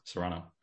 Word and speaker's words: Serrano: Serrano.